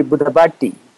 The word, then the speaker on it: Budabati: Okay, so it should be Budabati. – Buddhabati. Okay, good. Yes. So I didn't put the right emphasis on it.